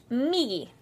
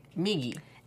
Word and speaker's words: migi: Which is H-I, for Romaji, it's H-I-D-A-R-I. So hidari. – Hidari. – And to the right is migi. – Migi. – M-I-J-I. – Did I say it right? – Yeah. Well, it's M-I-G-I. So, migi.